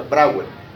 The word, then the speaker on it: Brouwer: se pronuncia Brouwer, se escribe Brouwer.